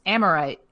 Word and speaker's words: Amirite: And the word is spelled Amirite and it's pronounced Amorite. – Amorite? – Yeah, it's slang for am I right? – Am I right?